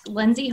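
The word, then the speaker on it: Lyndsey: Yeah, so my Instagram is at Lindsay Harper, but my name is spelled funny.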